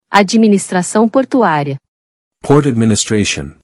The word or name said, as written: Administração portuária